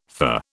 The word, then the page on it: fah: British English